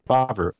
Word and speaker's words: Favreau: John Favreau? – Yep. – Favreau.